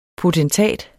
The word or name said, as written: potentat